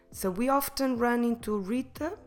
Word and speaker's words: Rita: In English, of course, Rita, but or Rita, but in Portuguese, Rita, with a rolled R here in your throat. Rita.